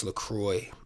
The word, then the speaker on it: LaCroix: I said, hey, so is it really pronounced LaCroix? And they said it's actually pronounced LaCroix. And then they wrote it C-R-O-Y.